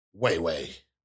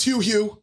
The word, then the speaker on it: Hue-Hue: It says, like when you Google it, right in the little Google caption, pronounced Wei Wei. Nope, it's Hugh Hugh.